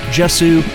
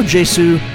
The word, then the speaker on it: Jesu: That's from a band called Jesu, or Jesu. I've heard it pronounced both ways.